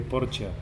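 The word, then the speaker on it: Porchia: Él tenía un maestro que también es poeta, que se llama Antonio Porcia, que se escribe Porcia, es Antonio Porcia en italiano, que es argentino.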